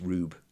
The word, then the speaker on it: R/B: The one we're watching right now, it's written as Ultraman R/B, but it's pronounced Rube. – Okay. – Rube.